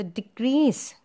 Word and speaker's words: decrease: Now when you use the word decrease as a verb, it says to decrease the amount of students. So decrease. The second syllable is stressed there.